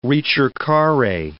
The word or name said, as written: ricercare